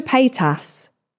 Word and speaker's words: Paytas: Trisha Paytas. Is that how you say it? Yeah.